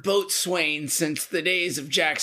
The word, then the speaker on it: boatswain: at the rank of boatswain's mate third class. Am I saying that right, Dev? Boatswain. – Yeah, but that's like I feel like that's a made up title.